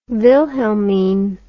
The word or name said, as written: wilhelmine